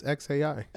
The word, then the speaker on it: xAI: It just says, it's just X, like the letter X, then AI. Like how you said the letter A, then I. So XI? XAI? – Maybe it's XAI.